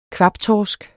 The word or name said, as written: kvabtorsk